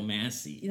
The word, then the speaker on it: Massey: It's Michael Massey.